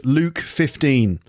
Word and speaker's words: Luke: Don't forget that you can get a 15% discount on all of Lucy's new pronunciation courses if you use the offer code Luke15, and that's L-U-K-E-1-5. L-U-K-E, okay, not... L-O-O-K or L-U-C-K. It's Luke, not look or luck, okay?